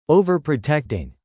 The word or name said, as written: overprotecting